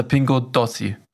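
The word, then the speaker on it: Pingo Doce: Eigentlich heißt der ja Pingo Dolce, so habe ich den genannt. Ja. Und eigentlich heißt er Pingo Dossi. Oh. Weil C und E schreibt man, spricht man anders aus, wie auch immer.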